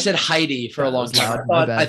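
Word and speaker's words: Hedi: People say Eddie, like just drop the H completely.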